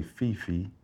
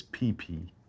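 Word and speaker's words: Phi Phi: Most people say Phi-Phi, but it's PP and Phuket, not Phuket. – What's that? – H is silent, bro.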